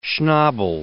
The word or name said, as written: Schnabel